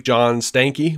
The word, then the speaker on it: John Stankey: AT&T Chief John Stanky, which apparently that is how you say that name.